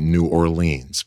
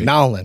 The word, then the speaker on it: New Orleans: Okay, Carly is the worship leader at Calvary Baptist Church in my part of the country, we would say New Orleans, but I know that's not how they say it in the, you know, down there. They say Nolens, I think is right. – Nolens. – Right? Nolens, yep.